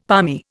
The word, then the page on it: palmy: Indian